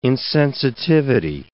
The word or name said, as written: insensitivity